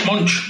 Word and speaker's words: Moench: Eh, Está igual. Doug Moench. Monch, Monch, Monch.